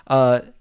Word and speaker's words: Cuthbert: Alicia, I don't remember how her name is pronounced. – Cuthbert, I believe. – Cuthbert. Her. She is my Lisa.